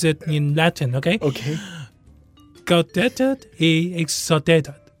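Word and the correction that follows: Gaudete et exsultate: Gaudete et exultate.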